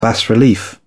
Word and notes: bas relief: British English

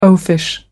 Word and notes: oafish: American English

